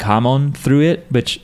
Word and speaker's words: hamon: Technically, it's like hamon. Like it's you accent the first syllable more, but we end up just saying hamon.